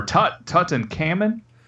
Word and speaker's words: Tutankham: Tutankham or Tutankhamun? – Tutankham.